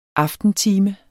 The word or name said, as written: aftentime